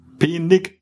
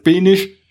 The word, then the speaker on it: Penig: Penig mit G hinten. – Penig. – So, und da die Sachsen aber alles Penisch aussprechen. – Ne? Klingt's wie Penis.